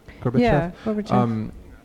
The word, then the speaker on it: Gorbachev: Gorbachev? – Gorbachev.